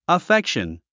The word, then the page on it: affection: əfékʃən; アフェクション